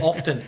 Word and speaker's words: often: Often or often? I say often. I pronounce the T, but I know you don't. – I say often. – Yeah, you don't pronounce the T, but I do.